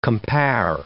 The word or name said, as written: compare